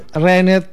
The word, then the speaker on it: ruined: The ending ruined it for me. – Ya. Yeah. – Itu bacanya ruined, ruined. – Ruined. – Ya yeah, ruined. Ada D-nya dikit.